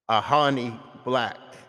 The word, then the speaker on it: Ajani Black: Hip hop recording artist, Ajani Black.